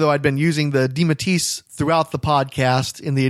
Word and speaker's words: DeMatteis: And after the interview, he and I discussed the pronunciation of the name, and we determined at that time that we were pronouncing it correctly by saying DeMatteis.